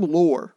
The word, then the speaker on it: Loehr: Number 7 is a book by Jim Lohr. Jim, and then it's spelled L-O-E-H-R, and it's called The Only Way to Win.